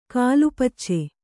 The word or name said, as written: ಕಾಲುಪಚ್ಚೆ